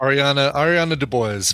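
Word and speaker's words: Ariana DeBose: Ariana DeBois.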